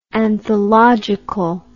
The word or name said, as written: anthologically